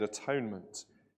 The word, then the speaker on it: atonement: Or verse 13, because he was zealous for the honor of his God and made atonement for the Israelites. This word, which is so profound to us as Christians, atonement, or as it should really be pronounced, at-one-ment.